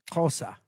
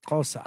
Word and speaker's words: Xhosa: The language is Xhosa. That's how they say it, Xhosa.